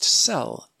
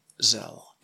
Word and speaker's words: Szell: He recorded many concertos with the great George Zell and the great Cleveland Orchestra. I ought to stipulate right now that that name, Zell, S-Z-E-L-L, is pronounced Sell in Hungary, but here in America, we've always said Zell.